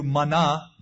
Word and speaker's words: manna: So when the children of Israel saw it, they said one to another, it's manna, or literally, what is it? Manna, pronounced in Hebrew, mana, means, what is it?